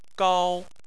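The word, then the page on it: gao1: tone 1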